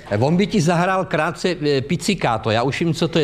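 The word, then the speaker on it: pizzicato: Ale je to picikáto, Vladimíre. To je správná výslovnost, je picikáto. Nikoliv to, co ty tak. Považuješ za sprostý, to je špatná výslovnost.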